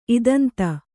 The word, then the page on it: ಇದಂತ: kannada